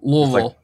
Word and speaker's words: Louisville: But no, yeah, you have to like blur it together and make it not have as many syllables. Like Louisville.